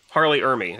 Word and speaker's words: R. Lee Ermey: Harley Ermy? Full- R. Lee Ermey.